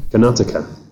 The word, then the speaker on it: Karnataka: which is Karnataka, and I've probably got the pronunciation wrong. – Karnataka. – Thank you.